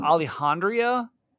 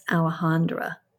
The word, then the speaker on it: Alejandra: It's A-L-E-J-A-N-D-R-A. So is it Alejandria? – I think it's Alejandra. – Alejandra. – Because J's are usually pronounced as H's.